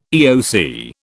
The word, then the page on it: EOC: British English